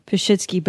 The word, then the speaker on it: Pashitsky: And on the other hand, though, I mean, we all got called stuff growing up, like because of my last name, Pashitsky was huge. And even to this day, when people are like, oh, Pashitsky. – How do I say your name? – Pashitsky.